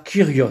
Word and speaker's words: Kyrios: Et donc ce nom de Dieu, Yahvé, il est rendu en grec par Seigneur, ou on traduit aussi en grec par Kyrios. – Kyrios. – Oui, mais Kyrios aussi, on prononce aussi avec un U, peu importe.